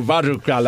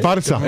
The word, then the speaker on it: Barça: Bueno, tú quieres pronunciarlo Barca, la C. – Barça.